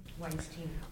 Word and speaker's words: Weinstein: This Weinstein moment, or Weinstein, how do you pronounce his name? – Weinstein.